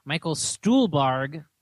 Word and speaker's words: Stuhlbarg: But I will say Michael Stuhlbarg. – Yes, finally said it right.